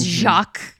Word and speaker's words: Jacques: The fact that it's not even just Jack, like it's Jacques, it's like specifically written and pronounced in French way, is incredible.